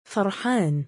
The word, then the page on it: far7aan: male